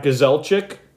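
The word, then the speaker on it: Grzelcyk: And he was assisted by Smith and Matt Gazelchik. Yes, I pronounce it the Polish way, not the American Canadian way, which is butchered all the time.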